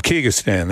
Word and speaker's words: Kyrgyzstan: In Central Asia, Kazakhstan, how do we pronounce this one? Kyrgyzstan. Kyrg- Kyrgyzstan, that's right.